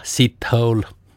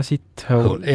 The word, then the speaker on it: Sithole: Tema nimi, kuula nüüd, on Jaja Sithole. – Jaja Sithole. – Jah. – Kuidas niimoodi tundi? Hääldataksegi? – No, kirjutatakse S-I-T ja hole, nagu inglis keeles on see.